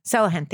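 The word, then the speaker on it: Sulahian: But like I remember Peggy Sahalian. What is her last name? I always say it wrong. Sellahan. Thank you.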